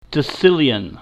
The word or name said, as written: decillion